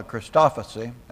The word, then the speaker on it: Christophany: in the form of a Christophany, or however you pronounce that, huh? Christophany. Thank you.